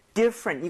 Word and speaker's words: different: 이 different 할때 많은 분들이 강세를 잘못 줘서 different 사음절처럼 발음을 하시더라고요. 근데 그게 아니라 I의 강세를 꽝 찍고요. – 네. – 거의 T는 흡수되는 느낌이에요.